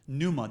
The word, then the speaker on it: pneuma: Pneuma, the P is silent for those that went to public school.